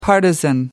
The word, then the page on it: partisan: American English